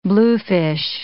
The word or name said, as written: bluefish